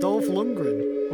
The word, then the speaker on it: Dolph Lundgren: You're saying his name wrong as well, Mike. – Dolph? What's his name? You say it. – Dolph Lundgren. – What did I say? – Lundgren, something like that.